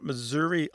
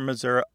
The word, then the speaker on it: Missouri: Because some Missourians don't say Missouri or Missouri, they say Missouri, more like that E in get or met. – Yes. – And that readily lends itself to becoming a schwa.